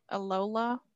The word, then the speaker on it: Alola: Alola.